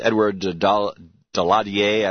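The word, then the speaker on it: Édouard Daladier: On the 18th of September, the French Prime Minister, this was Edouard Daladier, I believe it's pronounced, went to Czechoslovakia and said, "No, we're not going to give Czechoslovakia to Hitler."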